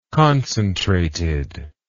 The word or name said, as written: concentrated